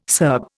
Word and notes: Serb: Indian

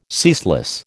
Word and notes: ceaseless: American English

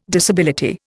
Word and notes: disability: Indian

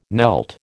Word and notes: knelt: American English

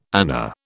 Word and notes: ana: British English

